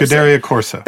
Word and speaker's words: Scuderia Corsa: And so with this particular car, I can't pronounce it correctly. It's the Scuderia? – Yes. – Corsa. Scuderia Corsa.